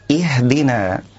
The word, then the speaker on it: اهدنا: هذه الآية الكريمة يقع بعض القراء في أخطاء شائعة حال تلاوتها، فمن ذلك إمالة همزة الوصل من كلمة اهدنا. البعض يقول اهدنا اهدنا، والصواب أن نحقق الكسر وأن نقول إي إي اهدنا.